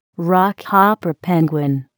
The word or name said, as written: rockhopper penguin